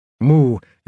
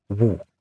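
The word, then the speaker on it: Mu (無): Mu is the Sino-Japanese word that I'm translating as No. It literally means not have or not exist. In modern Chinese, it's pronounced Wu.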